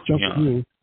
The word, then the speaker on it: Junkanoo: It looks like Junkanoo or Junkanoo. – Junkanoo.